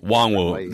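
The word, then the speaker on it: Nwangwu: Wongwu. The last two play plays is at Wongwu. – Okay, so let's go with his in college it was Nwangu.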